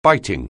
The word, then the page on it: biting: anglais